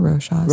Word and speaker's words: Rochas: Have you smelled anything by either Rocha or like, how do you say that? R-O-C-H-A-S? – I think Rocha. Rocha, right? – Yeah.